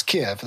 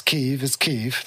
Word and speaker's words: Kyiv: Whereas like before that, everyone was like, it's Kiev, that's the place. But now it's like, it's Kyiv, that's the name of it. Uh-huh. We're going to say it properly.